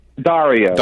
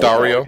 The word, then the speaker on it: Daurio: Who uh, between you and your writing partner, Ken, is it Daurio? Is that how you say his name? – Daurio. – Daurio.